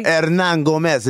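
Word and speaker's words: Hernangómez: Willie Hermangas. – Hernan Gomez, yeah. – Is that how they say it? Is that how we're saying it for today? – Hernan Gomez, if you want to impress the Dominicans.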